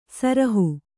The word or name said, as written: ಸುರುಹು